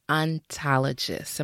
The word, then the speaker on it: ontologist: I know you call yourself an ontologist.